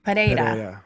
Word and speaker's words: Pereira: I can never say his name. Pereira. You have to roll the tongue, right? – Yeah.